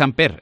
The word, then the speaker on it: Ampere: Yo digo Ampere, es Ampere, entonces se pronuncia así, lo digo porque, y así lo decimos correctamente, Ampere Energy en inglés o sí. Ampere, como tú prefieras.